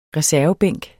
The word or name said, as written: reservebænk